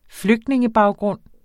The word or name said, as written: flygtningebaggrund